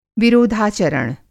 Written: विरोधाचरण

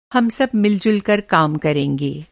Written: मिलजुल

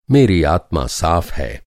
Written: आत्मा

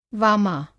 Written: वामा

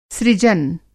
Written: सृजन

